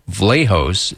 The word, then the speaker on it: Vlahos: That's spelled K-E-L-L-E-Y, Bokar, B-E-A-U-C-A-R, Vlahos. And that's V, like Victor, L-A-H-O-S.